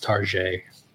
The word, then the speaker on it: Target: It's pronounced Target. – Target. – Yeah, it's French. That's how we pronounce it.